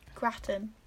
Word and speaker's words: gratin: How do you say that? – Gratin. – Gratin, yeah. It's like wow. Latin, but food. – Latin, but gratin.